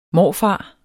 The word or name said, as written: morfar